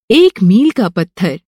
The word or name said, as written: मील